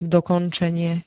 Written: dokončenie

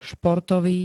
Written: športový

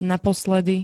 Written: naposledy

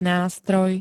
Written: nástroj

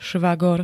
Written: švagor

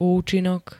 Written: účinok

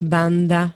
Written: banda

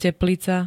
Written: teplica

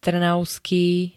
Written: trnavský

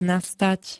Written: nastať

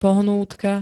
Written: pohnútka

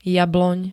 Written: jabloň

